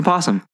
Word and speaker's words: opossum: It's spelled O-P-O-S-S-U-M. Do you not pronounce the O? – I don't think I ever have. I've always just said, oh, look, like a possum. – I think you say an opossum.